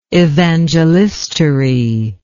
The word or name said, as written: evangelistary